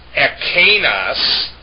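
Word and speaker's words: ekinos (ἐκεῖνος): It means this, it points out something near at hand in the Koine Greek, while the remote demonstrative, ekinos, pardon me, I mispronounced that, it's ekinos, it's not ekinos, it's not ekinos, it's ekinos, means that.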